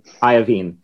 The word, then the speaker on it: Iovine: Iovine. Am I pronouncing that correctly? – Iovine.